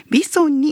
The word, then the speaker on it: VISON: 今回はですね、三重県のほぼ中央に位置する多気町というところにありますホテルやマルシェ、そしてさまざまな飲食店が集まる商業リゾート施設 VISON と書いてビソンにお邪魔しまして、運営会社ビソン滝代表取締役の立花哲也さんにインタビューしてきました。